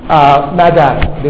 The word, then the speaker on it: Magi: No, I don't know how it's pronounced. Magi is correct? Okay. Magi, the wise men.